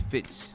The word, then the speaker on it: Fistz: I know my last name is Fitz, F-I-S-T-Z, like fist, like a, like I punch you with a fist with a Z, right? The S is silent.